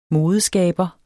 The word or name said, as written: modeskaber